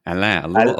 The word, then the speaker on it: Alain: Alain, a little higher.